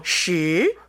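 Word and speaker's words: 시 (十): 숫자 10의 발음은 어떻게 되죠? – 시. – 시.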